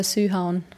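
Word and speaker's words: Sydhavn: Ich muss das Wort geschrieben sehen, aber Südhauen wird auf jeden Fall Sühauen. – Okay. Sühauen, ja.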